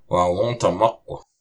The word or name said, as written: Wáwôtamuq